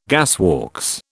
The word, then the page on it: gasworks: British English